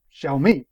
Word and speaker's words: Xiaomi: And Xiaomi is Xiaomi. I don't particularly mind.